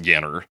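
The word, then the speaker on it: Ienner: Like, yeah, his group that was with Jimmy, I'm assuming it's pronounced Yenner. – Yeah. – It's I-E-N-N-E-R.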